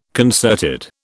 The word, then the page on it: concerted: British English